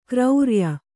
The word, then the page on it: ಗುರುರಾಯ: guru rāya